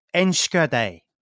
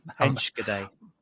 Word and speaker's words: Enschede: But I did have a bit of research into it, and apparently it's Enschede.